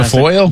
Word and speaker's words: Guilfoyle: Guilfoyle.